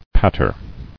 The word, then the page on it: Patterer: pat·ter